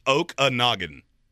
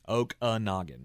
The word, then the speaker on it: Okanagan: It's Okanagan. – Okanagan.